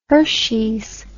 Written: Hershey's

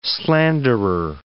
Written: slanderer

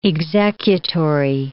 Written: executory

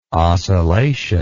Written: ocellation

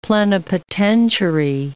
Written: plenipotentiary

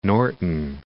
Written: Norton Shores

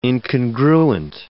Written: incongruent

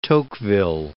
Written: Tocqueville

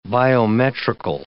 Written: biometrical

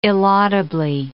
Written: illaudably

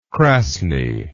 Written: krasny

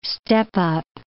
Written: step-up

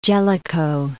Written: Jellicoe

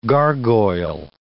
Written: gargoyle

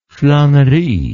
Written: flanerie

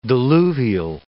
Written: diluvial

